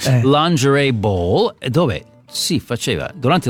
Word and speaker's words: lingerie: Un altro spoof è quello della lingerie ball. – Si dice lingerie. – Ah, lingerie, lin, perché francese si pronuncia così, lin.